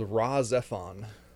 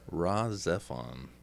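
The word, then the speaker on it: Rahxephon: It's called Razzephon. – Razzephon, like, okay. R-A-H space then Z-E P-H-O-N, I think that's how it's spelled.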